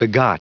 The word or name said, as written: begot